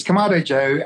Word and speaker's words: Kamado Joe: Yeah, it's Kamado Joe.